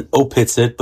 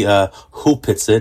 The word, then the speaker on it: Hupitzit: It's written Opitzit, but it's actually pronounced properly Hupitzit.